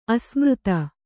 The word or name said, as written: ಅಸಮರ್ಥ